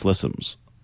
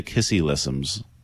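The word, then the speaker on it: Ksi Lisims: Now, I read this is pronounced Slissims. Now, many say Kissilissims or Seelissims.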